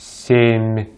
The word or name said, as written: семь